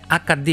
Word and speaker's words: HD: το High Definition το λένε AKD γιατί το H εδώ προφέρεται AKD σε AKD ανάλυση λοιπόν για τους Ιταλούς υπάρχουν υπότιλοι και όλα κομπλέ.